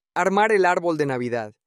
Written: armar el arbol de Navidad

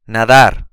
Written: nadar

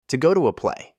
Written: to go to a play

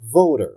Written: voter

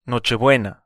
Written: Nochebuena

